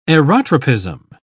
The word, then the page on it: aerotropism: English; British English